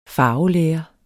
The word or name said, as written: farvelære